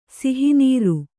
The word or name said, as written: ಸಿಹಿನೀರು